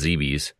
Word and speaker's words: Zebes: But the game, the original Metroid, took place on planet, I believe it's pronounced Zeebes. Z-E-B-E-S. – Zeebs. I always pronounce it Zeebs. – Zeebs, yeah. – It could be either way.